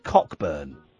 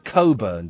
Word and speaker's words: Cockburn: Here's an interesting name for the police officer investigating it, and I know how it's pronounced, but it's his name is David Cockburn. They pronounce it Coburn, don't they? It's spelt Cockburn.